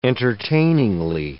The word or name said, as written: entertainingly